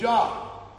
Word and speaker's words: Job: And then I realized in a few minutes he was quoting Eov, which he calls Job. I think they generally pronounce it in English. How do they pronounce it? Job.